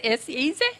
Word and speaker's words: says: Mine's a bit lame compared to everybody else's, but who says S-A-Y-S? – Mm. – She says S-A-Y-S is pronounced, she says, S-E-Z. – Mm. Okay. S-E-Z is usually a cartoon version of S-A-Y-S, but the pronunciation is certainly I say, you say, he says.